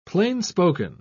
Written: plain-spoken

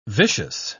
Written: vicious

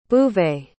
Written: bouvet

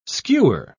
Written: skewer

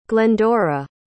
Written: glendora

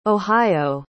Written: ohio

